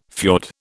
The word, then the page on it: fiord: British English